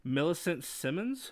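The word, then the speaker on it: Millicent Simmonds: Millicent Simmons, I believe? – Yes, you got it right.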